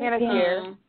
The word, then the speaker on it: Panettiere: I personally think so, just because I enjoy the storyline more, because it's Hayden, I'm going to butcher her last name, Panettiere? Is that how you say it? – Panettiere.